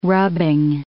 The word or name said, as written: rubbing